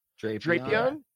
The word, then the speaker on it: Drapion: You know, I think that maybe a good example of that is like the Scorpion Pokemon, Drypin. How do you pronounce that? – Drapion.